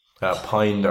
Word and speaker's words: Pijn: Pine. It's PIJN. Yeah. It's spelled like pigeon.